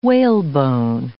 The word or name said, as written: whalebone